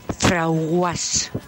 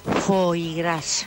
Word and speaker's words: foie gras: Pues yo tenía una clienta que cuando me iba a comprar cierta cosa decía, dame un frauguas. A ver si sabéis lo que es eso, porque yo el primer día no lo sabía. – ¿Era? – Foie gras. – ¿Qué? – Porque decía que tenía un nombre muy raro y ella no lo sabía pronunciar. Entonces lo decía así, Frauguas.